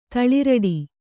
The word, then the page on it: ತಳಿರಡಿ: taḷiraḍi